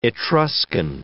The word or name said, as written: Etruscan